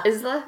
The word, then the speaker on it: Isla: Isla? – Okay. I looked it up. Nice. You'd think it would be Isla, but according to the Spanish pronunciation feature on Google Translate, Isla.